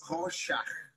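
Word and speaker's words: Rorschach: It's Horsack. It's a German name. – Horsack.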